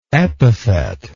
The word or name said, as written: epithetically